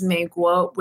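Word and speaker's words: Meiguo (美国): In Mandarin, the word for America is Meiguo, which literally translates into first character, beautiful, second character, country.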